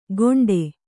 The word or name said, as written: ಘೋಂಟೆ